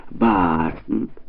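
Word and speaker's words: Boston: Правильное произношение — «Бастн».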